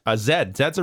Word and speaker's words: Zed: Zed's a real important one because that's how they pronounce the letter Z. Zed.